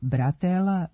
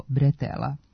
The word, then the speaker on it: bretela: Međutim, naramenica kao deo grudnjaka, potkošulje, kombinezona, letnje majice ili haljine, remen ili kaiš za nošenje tereta nije bratela, nego bretela. Potiče od francuske reči bretel, koja u izgovoru ima nemo e, a ono se u naš jezik prenosi kao e.